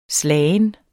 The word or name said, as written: slagen